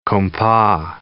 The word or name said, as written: compas